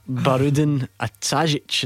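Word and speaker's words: Bahrudin Atajić: Barudin Atajic. Is how you say it.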